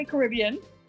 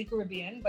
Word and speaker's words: Caribbean: Well, you can pronounce it one of two different ways, right? Some people will say Caribbean, and that's perfectly fine. And that could be like Jamaicans might say Caribbean, but in the Bahamas, we tend to say Caribbean.